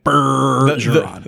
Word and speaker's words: Bergeron: It's Bergeron.